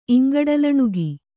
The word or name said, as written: ಇಂಗಡಲಣುಗಿ